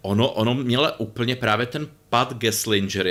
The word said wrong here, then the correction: Gelsinger: jestli ho vyslovuju správně, který teď eh, Gelsinger. – Gelsinger, který teď no.